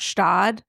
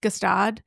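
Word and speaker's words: Gstaad: Stad, which I pronounced Gestad, what for such a long amount of time.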